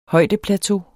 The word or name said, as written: højdeplateau